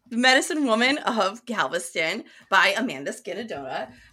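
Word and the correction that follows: Skenandore: Skinnador. – Skinnador. God damn it. Okay. I said it right.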